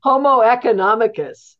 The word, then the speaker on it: homo economicus: So it's there's all sorts of different metaphors that are out there, and homo econom, how did you say it? – Homo economicus.